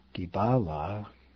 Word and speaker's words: Dibala: Dibala. Actually, let me try that again. Dibala, accent middle syllable.